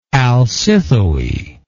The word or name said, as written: alcithoe